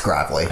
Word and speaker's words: Gravley: Yeah, people pronounce it Gravely like all the time, so, I mean. – Okay. – Yeah, but okay. You pronounced it right. Yeah, it's Gravely.